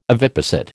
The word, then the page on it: oviposit: American English